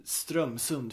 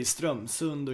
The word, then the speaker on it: Strömsund: Vi har uttalat Strömsund fel. – Ja. – Man säger Strömsund och inte Strömsund.